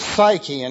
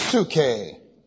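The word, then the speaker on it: psuche (ψυχή): By the way, they often pronounce this psyche in English. The Greek word is pronounced psuche.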